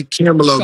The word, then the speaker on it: Camelo: That's just, say it like this. Hold on, do it with me. Ready? Camelo, baby.